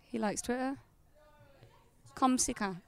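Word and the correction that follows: comme ci comme ça: Is that how you say it? Komsika. – Komsi komsa. – That's it. I just said komsika.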